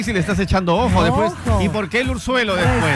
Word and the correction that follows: orzuelo: ¿Cómo se dice, Urzuelo o Orzuelo? – No, es Orzuelo.